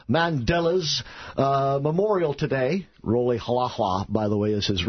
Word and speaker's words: Rolihlahla: Roli Halahla. – Roli Roli Halahla. – Halahla. – You spell it R-O-L-I-H-L-A-H-L-A. Roli Halahla.